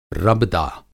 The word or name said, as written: रबदा